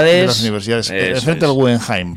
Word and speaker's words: Guggenheim: ¿Te has visto cómo yo pronuncio yo Guggenheim? Eso, ¿te has dado cuenta, no? – Sí, perfecto alemán. – Pareces de Porto. – No, es que todo el mundo lo dice en inglés.